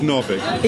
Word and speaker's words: Novick: It's Novick.